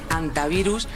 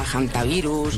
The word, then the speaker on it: hantavirus: No es infrecuente oírla quizá de ambas formas, pero lo adecuado es en efecto dejarla muda, decir antivirus y no aspirarla a hantavirus ni nada similar.